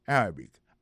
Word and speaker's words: Arabic: Prononciation correcte là, c'est Arabic.